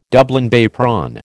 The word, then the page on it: Dublin Bay prawn: American English